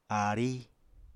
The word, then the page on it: aarí: Apatani